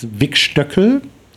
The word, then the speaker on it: Wigstöckel: It's Wigstöckel. Um, yeah. So as uh, sounds like the small daughter of Wigstock, and Stöckel also is in German high heel.